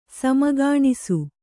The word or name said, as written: ಸಮಗಾಣಿಸು